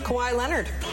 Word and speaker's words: Kawhi Leonard: How do we say his name? – Kawhi Leonard.